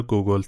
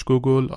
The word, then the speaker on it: گوگول: تلفظ اسمش به فارسی، روسی و انگلیسی کاملا متفاوته. امیدوارم ایراد نگیرید از اینکه من چرا گوگل تلفظش کردم.